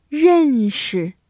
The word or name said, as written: 认识